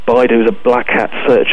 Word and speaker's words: Baidu: Now, you had mentioned earlier Baidu, as I hope I'm pronouncing that at least partly correct. – Yes, Baidu is, as, yes.